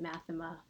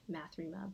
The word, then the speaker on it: Math3ma: I pronounce it Mathema. Some people say Mathrema, but you know.